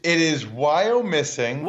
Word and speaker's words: Wyomissing: It is Wyomissing.